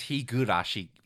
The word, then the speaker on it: Higurashi: The award goes for his constant mispronunciation of Higurashi. – It's Higurashi.